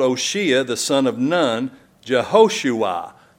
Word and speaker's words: Oshea: His name is Oshea. Now some people pronounce that O'Shea, but he wasn't Irish. He was a Hebrew. It's Oshea.